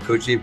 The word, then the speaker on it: Coogee: Down in I call it QG, apparently it's called Coogee, but you know, COOG, so I think it's pronounced OO. So I'm down in the Coogee down in Sydney.